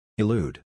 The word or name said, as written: Elude